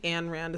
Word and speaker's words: Ayn Rand: I think Ayn Rand is how you're supposed to pronounce it.